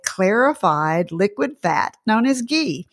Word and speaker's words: ghee: Spelled G-H-E-E, pronounced ghee.